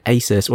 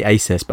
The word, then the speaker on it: Asus: So I had the choice between getting an Asus or Asus. Is that how you say it? – I would say Asus. – I say Asus, but apparently it's Asus.